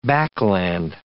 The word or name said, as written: backland